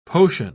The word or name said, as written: potion